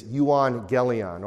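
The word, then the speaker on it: euangelion (εὐαγγέλιον): So we spell the word evangelize, E-V-A-N-G-E-L, so on and so forth. In Greek, there's no letter V. In Greek, the letter there is an upsilon, which looks like a U. So you would pronounce it in Greek as euangelion or euangelizo.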